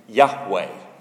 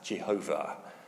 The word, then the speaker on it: Yahweh: You might pronounce it Yahweh. Yahweh. In former times, it was incorrectly translated as Jehovah.